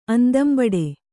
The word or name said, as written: ಅಂದಂಬಡೆ